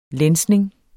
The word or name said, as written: lænsning